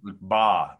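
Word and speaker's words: Mbah: Mba. The M is silent.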